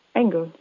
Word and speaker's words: Engel: And my last name, Engel, actually means angel in English. For the pronunciation in German, it's a bit like the English word Engel. Engel, so at an angle.